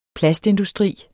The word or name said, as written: plastindustri